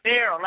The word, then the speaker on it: Beryl: We're from Beer Martin. Am I pronouncing that correct? – Beryl Martin. Mm-hmm. – Beryl, like barrel. – There you go. – But it's not spelled like barrel. – It's spelled actually with the owner's mother's name was Beryl, B-E-R-Y-L.